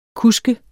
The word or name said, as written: kusk